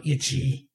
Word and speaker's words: Ichi: No, Ichi. – Ichi, like Ichi with an E-C-H-I? – I-C-H-I. – That's not how you spell itch. – It means one. – It's a Japanese word.